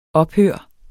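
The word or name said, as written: Ophør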